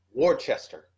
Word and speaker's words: Worcester: Because that's how it's spelled. It's W-O-R-C-E-S-T-E-R, which in all sense means Worcester. But it's Worcester.